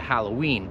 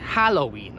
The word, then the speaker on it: Halloween: I też tutaj powiem, jak się wymawia Halloween, bo ja nie wiem, tak w Polsce jakoś dziwnie to wymawiać. Mówisz tak Halloween albo coś takiego, ja nie wiem jak to powiedzieć. To jest Halloween. Oczywiście po angielsku.